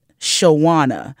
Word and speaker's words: Shawana: Like the pronunciation of it is Shawana. – Exactly. – It's not Shawana.